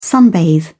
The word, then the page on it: sunbathe: British English